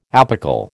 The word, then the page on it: apical: American English